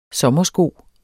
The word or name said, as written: sommersko